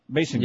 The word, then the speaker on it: Basinger: Oh, we got a nice picture, I should point out, of Kim Basinger. Basinger. – Yeah, good.